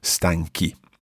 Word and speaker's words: stanchi: And then to keep that hard C sound, we need to put in an H, stanchi.